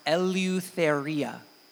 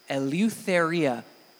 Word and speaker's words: Eleutheria: When Paul says you are called to freedom, he uses a word called, that's pronounced Eleutheria. Eleutheria.